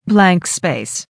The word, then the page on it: blank space: American English